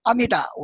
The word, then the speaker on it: 阿弥陀: 应该这样念的，那就是阿弥达，我们都念读成阿弥陀，阿弥达，南无阿弥达布达雅，布达雅不是觉着佛的意思吗？